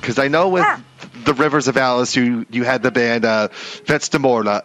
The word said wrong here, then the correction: Vetusta Morla: Is that how you pronounce it? Vets de Morla? – Vetusta Morla. – Vetusta Morla. – Well done.